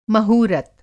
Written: महूरत

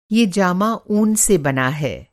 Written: जामा

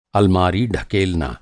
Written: ढकेलना

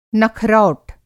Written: नखरौट

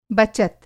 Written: बचत

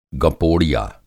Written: गपोड़िया

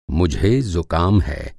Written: ज़ुकाम

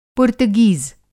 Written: पुर्तगीज़

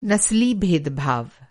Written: नसली

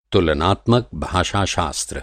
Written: तुलनात्मक

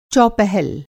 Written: चौपहल

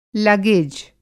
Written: लगेज

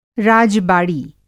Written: राज-बाड़ी

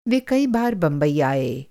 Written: बंबई

